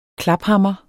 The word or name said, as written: klaphammer